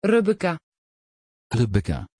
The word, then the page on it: rebekkah: Dutch